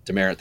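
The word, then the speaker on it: Demeritte: and Travis Demerit. I'm going to say it wrong, but that's okay. And Demerit. – Colin Moore. Demerit, thank you.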